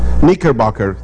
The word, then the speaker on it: Knickerbocker: Según Quinker Boxer. ¿Cómo se dice? Knickerbocker.